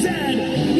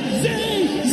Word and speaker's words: zed: The toque is a hat, a Chesterfield is a coach, and it is pronounced Zed, not Zee, Zed.